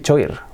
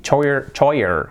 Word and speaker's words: choir: Yo esta ya no recuerdo cómo la pronunciaba, que decía choir, choir, o algo así, ni idea. ¡Wow! Agarraos a la pronunciación, que si no la sabéis, os va a llamar la atención, porque se dice... ¿Do you know? ¿La sabéis? No, se dice, fijaos, choir. ¡Choir! ¡Yeah! ¡Choir! Completamente diferente.